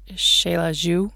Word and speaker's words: Xela: It's I believe it's the Spanish name given to the town, but the Mayan name was like Xelaju or something. I don't know how to pronounce it correctly, Xelaju, but it's X-E-L-A-J-U. And so everybody calls it Xela, is how you pronounce it, X-E-L-A.